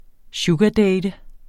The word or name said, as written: sugardate